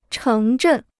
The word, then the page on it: 城镇: chéng zhèn